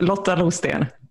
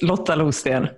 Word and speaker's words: Lotta Losten: Am I saying that right? – Well, almost. Lotta Losten. It's fine. – What? – Nobody ever says it right. It's Lotta Losten.